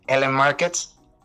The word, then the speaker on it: LN Markets: bueno, este, soy, eh, soy el Cypher Community Manager de Ellen Markets. Esa sería la manera en que se pronuncia.